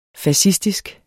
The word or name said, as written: fascistisk